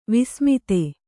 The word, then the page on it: ವಸುಮದ: kannada